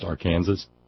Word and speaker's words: Arkansas: In all honesty, and Gail says, you know, one day you might hear me trying to say A-R-K-A-N-S-A-S and have your revenge. Well, I'm, you know, my parents were actually taught in grammar school back in the 1930s that was to be pronounced Arkansas, and it only became Arkansas later, or so I was told.